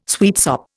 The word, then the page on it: sweetsop: Indian